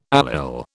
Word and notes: allyl: British English

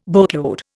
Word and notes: boatload: Indian

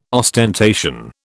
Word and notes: ostentation: British English

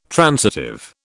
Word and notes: transitive: British English

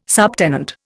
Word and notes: subtenant: Indian

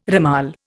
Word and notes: ramal: Indian

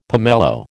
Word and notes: pomelo: American English

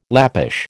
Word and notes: Lappish: American English

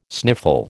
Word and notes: sniffle: American English

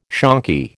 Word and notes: shonky: American English

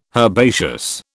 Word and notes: herbaceous: British English

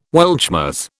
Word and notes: Weltschmerz: British English

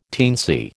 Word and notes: teensy: American English

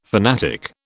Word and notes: fanatic: British English

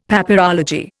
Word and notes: Papyrology: Indian

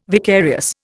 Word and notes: vicarious: Indian

